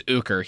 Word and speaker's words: Ooker: His name's Uker? – His name's Uker. He's a racist piece of shit. He deserved to die. His name is spelled O-O-K-E-R.